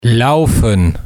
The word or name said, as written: laufen